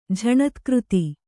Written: ಝಣತ್ಕøತಿ

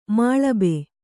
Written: ಮಾಳಬೆ

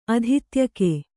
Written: ಅಧಿತ್ಯಕೆ

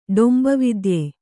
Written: ಡೊಂಬವಿದ್ಯೆ

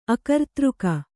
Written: ಅಕರ್ತೃಕ

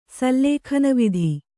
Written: ಸಲ್ಲೇಖನವಿಧಿ

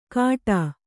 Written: ಕಾಟಾ

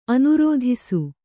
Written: ಅನುರೋಧಿಸು

